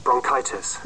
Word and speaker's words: bronchitis: Another one is the name of the illness that the original reader of Jirka's expedition came down with, and it should be pronounced bronchitis. – It should be pronounced bronchitis. Bronchitis.